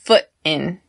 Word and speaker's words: foot: Notice how I say foot in, foot in, foot and mouth disease, right? So I do not pronounce that T at the end of foot, foot.